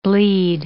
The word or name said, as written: bleed